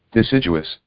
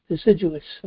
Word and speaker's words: deciduous: How do you pronounce that? Not sure. Let me. Deciduous, I believe it's pronounced. – Deciduous. – Deciduous.